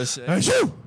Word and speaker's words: Aho: Who's their sick forward group other than Aho? – Achu. You're pronouncing it wrong.